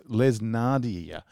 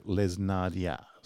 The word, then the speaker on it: Les Nardia: Next, Les Nardia. It says, it's like, because when I was looking up how to pronounce this, it says, it looks like Les Nardi, but it's like Les Nardia, something like that.